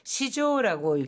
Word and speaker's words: 市場: 이 시장은 시조라고 읽습니다. 이 시장을 시조라고 읽는 것은 추상적인 개념의 시장이라든가 너무너무 큰 규모의 시장, 여론 시장이라든가 증권 시장 뭐 이럴 때는 전부 시조라고 읽죠. 반면에 뭐 남대문 시장이라든가 재래시장 이런 것들은 전부 1번. – 이치바라고 합니다.